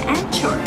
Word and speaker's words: anchor: no se pronuncia anchor, sino anchor.